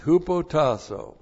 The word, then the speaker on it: hupotasso: So we start out with hupotasso. Great word, hupotasso. H-U-P-O-T-A-S-S, long O. Hupotasso.